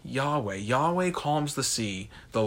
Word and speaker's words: Yahweh: And so many modern scholars believe that the proper pronunciation of God's name would be Yahweh. Yahweh. And so Y-H-W-H